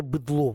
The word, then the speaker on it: быдло: Оказывается, мы все быдло. С ударением на «о», а тогда, значит, мы, оказывается, мы все быдло.